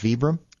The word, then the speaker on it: Vibram: When you look at the bottom of a hiking shoe, you see, is it pronounced Vibram? – Yeah.